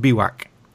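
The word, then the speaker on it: bivouac: Biwaks, but it's spelled funny French word, B-I-V-O-U-C or something. But of course, it's pronounced Biwak.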